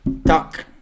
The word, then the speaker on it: tack: Tack så mycket. – That's too long. – Tack.